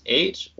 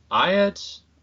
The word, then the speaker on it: eyot: And there's a word that is rather uncommon in English, and I looked it up, and it's spelled E-Y-O-T. Can be pronounced eight or iot, or I guess whatever you want.